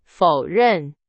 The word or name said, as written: 否认